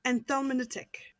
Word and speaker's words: anthelmintic: Anthelmintic.